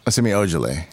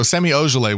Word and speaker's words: Semi Ojeleye: How do you say his name? – Semi Ojeleye. – Semi Ojeleye. – Yeah, right. There you go. So Semi Ojeleye was absolutely butchered.